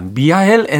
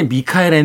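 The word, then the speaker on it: Michael: 미하엘 엔데의 모모라는 책인데요. 미하엘 엔데 당시에는 이제 미카엘 엔데라고 이 작가의 이름을 발음하기도 했었고 이 모모에 대해서 또 굉장히 감동받은 몇 가수가 또 여기에 대한 어떤 음악을 만들기도 했던 그런 기억도 납니다.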